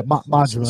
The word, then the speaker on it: Majima: Or it's either Majima or Majima. I don't know how you say it. – Yeah, Majima.